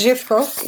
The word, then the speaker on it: Zhivkov: It's Zhivkov. – Zhivkov, yes.